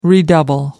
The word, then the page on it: redouble: American English